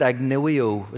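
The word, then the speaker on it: agnoeo: The word in the Greek is pronounced agnoio. It sounds a bit like, I know you.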